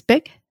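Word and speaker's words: Bích: So my given name, my birth name is Bick, and it's spelled B-I-C-H, and the I has an accent over it that nobody really uses, but it's pronounced Bick. It's a tonal, Vietnamese is a tonal language, but we could call me Bick, for instance, and that's what people would do.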